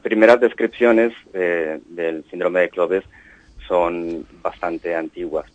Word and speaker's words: CLOVES: ¿Cómo pronunciamos el síndrome? – Cloves. – Cloves, perfecto. Iba a decir Clovis, pues no, Cloves.